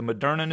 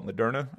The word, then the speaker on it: Moderna: Do I say that right? I don't know if I've actually listened to somebody say it or paid attention to how people say it, but I call it Moderna.